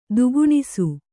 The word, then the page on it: ದಕ್ಕಣಿಸು: dakkaṇisu